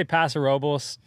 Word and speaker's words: Paso Robles: Paso Robles. Is that how you pronounce it? Is that correct? – You know, I say Paso Robles. A friend of mine says Paso. It's town today. You can say whatever you want.